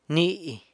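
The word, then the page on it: ni: San Lucas Quiaviní Zapotec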